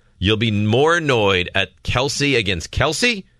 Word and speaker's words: Kelce: The Kelces. It's Kelce, though. – No. – I'm just telling you how it's supposed to be pronounced.